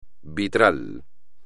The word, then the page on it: vitral: espagnol